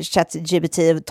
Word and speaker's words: ChatGPT: att på franska, då, då alltså uttalar man ju det ChatGPT. Vilket också låter exakt som när man som på katt. franska säger, Eller? säger eh, eh, katt jag pruttade.